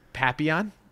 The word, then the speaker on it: Papillon: Anyway, after that, in 73, does Papillon.